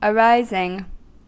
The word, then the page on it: arising: American English